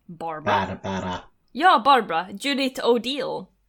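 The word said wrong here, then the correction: O'Dea: O'Dee, förlåt. Judith O'Dee.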